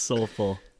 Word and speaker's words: SOLFL: SOLFL, pronounced soulful.